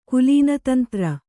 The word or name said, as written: ಕುಲೀನತಂತ್ರ